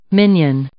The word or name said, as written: minion